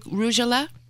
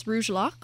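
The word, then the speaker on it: rugelach: Will you tell me again the name of the pronunciation of this one that I'm holding? The Rougelach? – I believe it's Rougelach.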